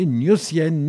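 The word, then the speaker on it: Gnossienne: Je vous propose d'écouter donc tout de suite la Nuocienne numéro 4, j'espère que je le dis bien, jouée par Alexandre Tarot au piano Séric Satie. c'était Eric Satie, euh, donc au piano Alexandre Tarot sur les Gnossiennes numéro 4.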